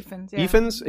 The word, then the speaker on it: Ifans: One thing that Reese, is it Reese Ifans or Ifans? How do you say his name? – Ifans.